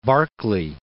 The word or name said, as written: Barclay